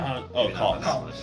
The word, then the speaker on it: KAWS: Cause. – How do you spell it? – K-A-W-S, all caps.